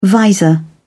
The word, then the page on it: visor: British English